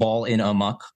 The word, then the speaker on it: Ballinamuck: At a place called Ballinamuck, or it's spelled Ball in a Muck, but I believe it's pronounced Ballinamuck.